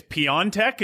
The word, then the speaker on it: Piontek: And did you say it's Piontek? Is that the correct pronunciation? – Yeah, so I saw on Di Marzio's side, we had an interview with Genoa's president, Preziosi, and he made a point of saying that's how it's pronounced.